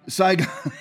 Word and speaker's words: Saigon: Joe Biden was a senator during Saga, uh, uh why am I pronouncing that wrong? Saigon, Saigon. I couldn't get Sag out of my head.